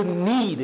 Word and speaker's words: knead: It ain't spelled N-E-E-D. It's spelled K-N-E-A-D. Imam Muhammad, a reader of this culture, Alhamdulillah Rabbil Alameen, Rahim Allah Hulay, he said, wherever you find a silent letter, there's a secret in that word.